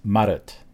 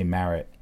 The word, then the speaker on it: Marotte: So Bindle Marotte, and is it pronounced Marotte? I don't know. I think in French it's Marotte. So I think in English I can say Marotte. Bindle is B-I-N-D-L-E, and Marotte is M-A-R-O-T-T-E.